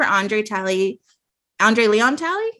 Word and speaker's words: Andre Leon Talley: They didn't do anything for Andre Leon Talley, did I say it right? – Yeah.